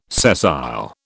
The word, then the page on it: sessile: British English